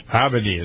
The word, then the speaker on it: Abedi: At number 2, Salman Abedi, or Abedi. How do you pronounce that? – Abedi is what I would say. – Abedi, yes.